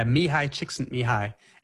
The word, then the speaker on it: Mihaly Csikszentmihalyi: Yeah, Mihaly Csikszentmihalyi.